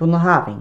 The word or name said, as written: BUNNAHABHAIN